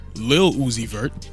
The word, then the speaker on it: Lil Uzi Vert: I'm sorry, Lil Uzi Vert. Pronounced Lil.